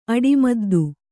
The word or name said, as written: ಅಡಿಮದ್ದು